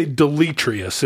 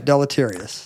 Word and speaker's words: deleterious: Because it's got an extra syllable. I said it right. Deleterious.